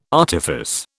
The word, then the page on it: artifice: British English